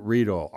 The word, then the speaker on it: Riedel: He's a great guy, and I encourage you to follow him on Facebook, Maximilian Riedel, R-I-E-D-E-L, rhymes with beetle. Okay, not Riedel.